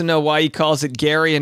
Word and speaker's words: Gerry: uh, Irish and Scottish say Gary and it's spelled G E R R Y huh, okay.